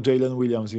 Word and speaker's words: Jalen Williams: Podobno się obu czyta dokładnie Jalen Williams, więc yy, to, jest, to, to, jest zabawne.